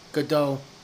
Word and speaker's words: Gadot: Is it Gadot or Godot? – Godot. – Godot. – Something. Like Waiting For? – Yeah. – The problem is it ends with a T.